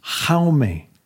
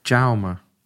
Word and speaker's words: Jaume: Jaume. – Jaume.